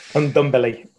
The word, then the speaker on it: Ndombele: So I'm going with Højbjerg, whatever his name is, and Belly, as I call him, because I can't say his name properly. – Ndombele. – Ndombele.